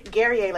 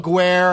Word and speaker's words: Guerrier: Guerrier, like Perry. – Guerrier. There you go. I was trying to go Guerre. I was trying to, you know, overpronounce it a little bit.